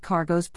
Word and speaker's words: Cargos: Another Indian design shown at 3D Experience World was an electric scooter called Cargos, pronounced like cargo.